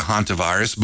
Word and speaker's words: hantavirus: I just heard this morning that there was a death in Colorado due to hantavirus. But again, I guess I'm saying it right. – Yep.